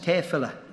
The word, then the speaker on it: Tefillah: First of all, they would say, "You pronounced it wrong. It's Tefillah," or Tefillah, T-E-F-I-L-L-A-H.